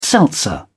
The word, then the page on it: seltzer: British English